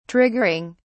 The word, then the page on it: triggering: British English